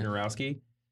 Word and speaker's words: Wojnarowski: uh, I'm gonna mispronounce his name, but it uh, had Adrian w- w- Wojnarowski. Wojnarowski. Yeah. Wojnarowski.